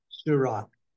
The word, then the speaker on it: Seurat: If not, it's by a famous artist, I can't really pronounce his name, Georges Seurat. – Seurat. – Seurat, okay, thank you.